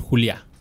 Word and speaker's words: Juliá: O su nombre artístico, Raúl Juliá. Que es como Julia, pero con pinche acento en la A.